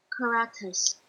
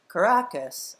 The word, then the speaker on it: Caracas: Caracas. Thank you. Caracas.